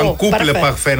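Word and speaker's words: en couple parfait: Αν κάπλο παρφέ, ένα τέλειο ζευγάρι. – Το παρφέ είναι το μόνο που μπορώ να πω, είναι σαν το παγωτό. – Αν κούπλε παρφέ, να το πούμε σωστή προφορά.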